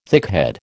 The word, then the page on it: thickhead: American English